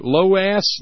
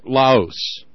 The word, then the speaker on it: Laos: Same thing for, how do they pronounce it? Loas? Loose? Laos. Laos.